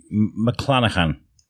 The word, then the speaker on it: Mclanachan: But and today we're speaking to local author Kate Mclanachan.